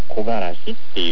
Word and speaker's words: 木枯らし: だから木枯らしっていう、木を枯らす風と書いて木枯らしというんですけど、これは季節が秋から冬へ変わるときにですね、初めて吹く強い北風のことを言います。